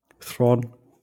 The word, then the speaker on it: Theron: Fand ich, ich habe es eigentlich nur angeguckt, weil da stand, es ist mit Charlize Theron und oder wie spricht man es richtig aus? Du hast es mich schon Thrawn.